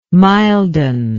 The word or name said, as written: milden